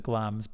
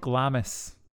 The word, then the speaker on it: Glamis: It looks like Glamis. – Why does one not pronounce the I? – Scotland.